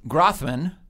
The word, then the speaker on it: Grothman: But, right, so you don't pronounce Glenn Grothman's name right. You say Glenn Grothman.